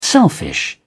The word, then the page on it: selfish: British English